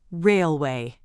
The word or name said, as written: railway